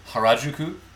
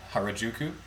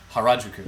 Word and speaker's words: Harajuku: So it's actually Harajuku, not Harajuku, which is how we pronounce it. They actually have the accents on completely different syllables than we do. – Mm. – It's Harajuku.